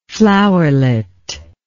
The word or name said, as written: flowerlet